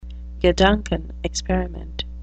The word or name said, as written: gedankenexperiment